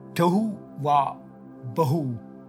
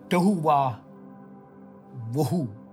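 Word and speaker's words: Tohu wa-bohu: Uh, if you want a deeper understanding of what I'm going to share about, and it's called the Tohu Wa Bahu effect, or as some others pronounce it, Hebrew scholars, the Tohu Wa Wahu effect. So it's not Bahu Bahu. Anyway, it's, it's how you pronounce it.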